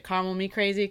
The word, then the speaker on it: Caramel Me Crazy: I do have, and it's funny because I was calling it caramel me crazy, and then I realized they probably want you to pronounce it caramel me crazy because it sounds like call me crazy.